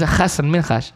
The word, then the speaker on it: Hassan Minhaj: It's Hassan Minhaj. – Hassan Minhaj. – Basically, you got to say it like you're saying his name backwards. Hassan Minhaj. Like you're in rewind.